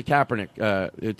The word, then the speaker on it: Kaepernick: So with the I had a thought on the Kaepernick taking the knee.